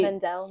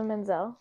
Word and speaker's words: Menzel: But yeah, that's because Idina Menzel. Menzel. – Is that Idina Menzel? – Is that how you pronounce her name? – Menzel.